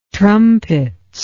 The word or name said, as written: trumpets